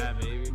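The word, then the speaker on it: Bhad Bhabie: So speaking of entertainers, man, we're gonna talk about Bad Bobby. Am I saying her name right? – Bad Baby. – Bad Baby. Yo, her name is so weird. It's the H's that throw me off.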